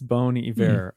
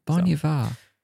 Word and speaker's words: Bon Iver: Yeah, I think it's Bon Iver. It means good winter in French. – Well, that's probably Like bone. Yeah, bone in French, not like bon, like I would normally pronounce B-O-N.